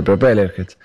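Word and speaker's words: Propellerheads: Pero Propeller Heads. No sé si se pronuncia así. – Sí, sí.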